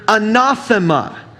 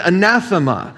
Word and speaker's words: anathema: He uses the word anathema. Or in English, sometimes we pronounce it anathema.